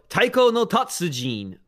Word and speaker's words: Taiko no Tatsujin: So Taiko no Tatsujin, specifically the drum and fun iteration of it, which is a hell of a name.